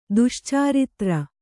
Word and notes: ದುಶ್ಚಾರಿತ್ರ: Kannada

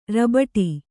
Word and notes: ರಬಟಿ: rabaṭi; dial.

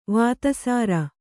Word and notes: ವತ್ಸರ: kannada

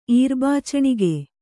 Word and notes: ಈರ್ಬಾಚಣಿಗೆ: kannada

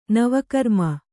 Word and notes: ನವಕರ್ಮ: nava karma

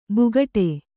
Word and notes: ಬುಗಟೆ: bugaṭe

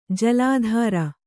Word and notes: ಜಲಾಧಾರ: Kannada

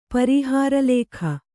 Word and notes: ಪರೀಹಾರಲೇಖ: parīhāra lēkha

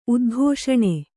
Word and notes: ಉದ್ಘೋಷಣ: kannada

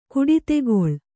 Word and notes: ಕುಡಿತೆಗೊಳ್: kannada

